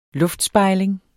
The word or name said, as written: LUFTSPEJLINGEN